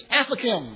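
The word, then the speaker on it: afikoman: I said, how do you pronounce this word? And she said, it's aphokim.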